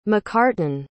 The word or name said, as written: macarten